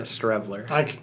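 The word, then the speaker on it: Streveler: It's pronounce it, because I kept calling him Scrivener, but on the TV they kept calling him Strevner. – Strevler. – I was pronouncing it wrong the whole time.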